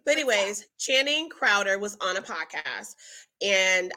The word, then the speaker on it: Crowder: chowder crowder crowder look i pronounced it wrong crowder there is a there was a clip circling around again, men with podcasts.